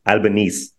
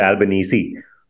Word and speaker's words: Albanese: In fact, a bunch of us in India, we thought Albo is pronounced Albanese. I learned during this event that he goes by Albanese, which makes sense because he did look quite uneasy.